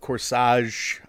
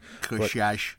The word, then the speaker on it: Corsage: Marie Kreutzer of Corsage. I don't think that's correct pronunciation. – Corsage. – Thank you.